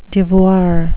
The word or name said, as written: devoir